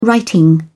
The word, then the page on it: writing: British English